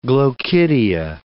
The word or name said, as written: glochidia